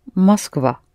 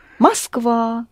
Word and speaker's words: Москва: Mm-hmm, yes, but the first or is not accented, so you pronounce it as а. – Москва. Москва. – Да, Москва, yes.